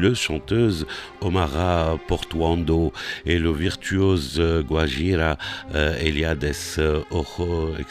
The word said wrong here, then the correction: Ochoa: la prononciation Ochoa, je veux dire, enregistré en seulement 6 jours au studio Egrem.